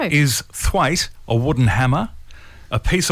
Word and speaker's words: thwaite: Today's word is thwaite. – Thwaite. – Thwaite. – Thwaite. – And it's spelt the way you think it is. – Like Thistlethwaite. – Yes. – Like Ray Thistlethwaite from Thirsty Merc.